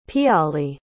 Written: piyali

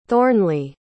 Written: thornley

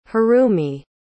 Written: harumi

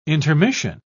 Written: intermission

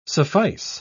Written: suffice

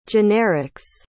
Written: generics

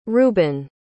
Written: ruben

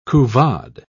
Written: couvade

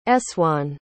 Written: essoin

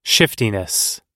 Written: shiftiness

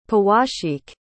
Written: powasheek

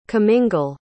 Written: comingle

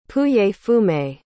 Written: pouilly-fumé

